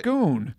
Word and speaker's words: Scone: The stone of Scone. Did I say that right? – Scone. – Scone. There's not two O's in that. – It doesn't matter. That's how it's pronounced.